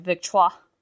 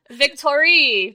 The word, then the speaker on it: Victoire: Oh, man, that must have been an audiobook thing because I was not pronouncing it Victoire. – Yeah, oh, that's... – I don't know how to pronounce French. – Oh, yeah, that's just how you say that name. – I was like, oh, yeah, Victorie, because victory, great. So she was Victorie the whole time.